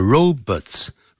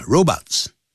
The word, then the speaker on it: robots: 但不管怎么样 caring 就是满怀关心的贴心的 My caring parents are visiting me tomorrow 也就等于 my loving parents 我满怀爱心的爸妈明天会来看我 Robots 这个字不要念成 robots 叫 robots 好我们现在先为大家把这篇文章念一下 Nowadays, people can expect to live 15 years longer than people did 50 years ago This is putting a lot of pressure on providers of healthcare However, one company, InTouch Health Inc.